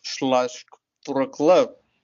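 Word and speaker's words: Śląsk Wrocław: And if we're talking about Slask Vlorklaw, that's how it's pronounced.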